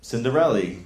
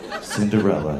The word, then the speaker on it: Cinderella: Cinderella. – All right. You know, in the first round, it's cute to mispronounce things, but... – Right, sorry. Cinderella.